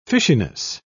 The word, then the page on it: fishiness: British English